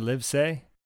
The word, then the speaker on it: Livesay: And we're joined today by Fred Livesay. And I hope I pronounced that right. I just went phonetically. – Yes, that's right. Awesome. Like I live in a house.